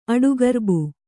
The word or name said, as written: ಅಡುಗರ್ಬು